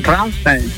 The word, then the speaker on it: Rammstein: Decinos cuál es tu canción favorita de Rammstein. – Rammstein. – Pues sí, ¿ves? – El tema homónimo. – Pues sí. Pronuncie. Rammstein. – Rammstein.